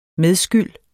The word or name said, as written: medskyld